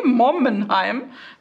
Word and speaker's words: Mommenheim: Okay, Mommenheim.